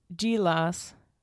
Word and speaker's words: Gila: So it's called Adobe Gilas. Which is what the website says phonetically. Gila. Adobe Gila.